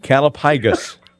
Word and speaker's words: callipygous: Calipygus. – Calipygus, C-A-L-L-I-P-Y-G-O-U-S, and according to the wordsmith, it is pronounced calipygus.